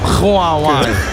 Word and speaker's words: Huawei: Huawei, of hoe spreek je dit nou in hemelsnaam uit? – Mag ik het doen? – Ga je gang. – Huawei. – Huawei. – Keurig. Nee.